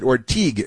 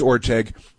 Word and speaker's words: Orteig: So that's, and again, they mispronounced it Orteig. It's Orteig, but that's okay.